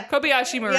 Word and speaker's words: Kobayashi Maru: It's Kiriyashi Maru. – Yeah. – Kobayashi Maru.